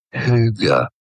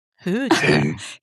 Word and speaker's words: hygge: How do you say it? Hygge. – Hygge. – Hygge. Hygge. What website? Are you... Jill, you're going to have to clean your cookies after this. – Hygge.